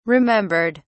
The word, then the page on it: remembered: British English